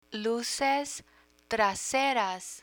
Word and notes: luces traseras: Spanish

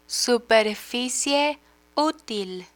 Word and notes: superficie util: Spanish

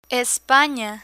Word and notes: espana: Spanish

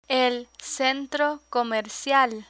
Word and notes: centro comercial: spanish

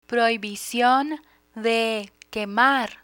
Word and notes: prohibicion de quemar: Spanish

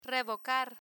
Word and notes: revocar: Spanish

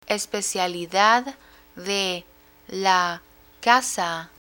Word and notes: especialidad de la casa: Spanish

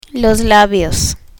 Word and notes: labios: Spanish

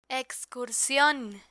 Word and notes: excursion: Spanish

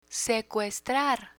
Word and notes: secuestrar: spanish

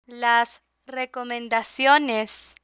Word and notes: las recomendaciones: Spanish

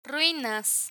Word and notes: ruinas: spanish

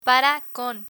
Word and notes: para con: Spanish

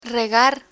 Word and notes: regar: Spanish

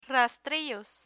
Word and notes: rastrillos: spanish

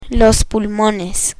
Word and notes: pulmones: spanish

